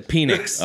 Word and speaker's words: Penix: He changed the pronunciation. – To what? – To Penix.